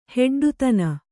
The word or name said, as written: ಹೆಡ್ಡತನ